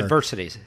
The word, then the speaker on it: adversity: Adversary score, for some reason that took me, you had to help me through that. I couldn't get that out without you, so hopefully, you know, my English is a little bit better. – Adversity score, yeah. – Did I say it wrong? – You said again? – It wrong again.